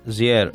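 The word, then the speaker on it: JR: Πώς τον προφέρεις τον καλλιτέχνη? – Ζιέρ. – Ναι.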